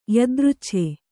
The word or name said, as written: ಯದೃಚ್ಛೆ